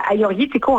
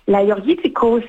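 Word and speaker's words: Agiorgitiko: Vous êtes très bonne, parce que pour ceux qui nous écoutent, le Aioritiko, en fait, c'est deux G, mais les G, on les prononce comme les Y. Hein? Donc, l'Aioritiko, c'est un cépage qui s'épanouit dans le Péloponnèse.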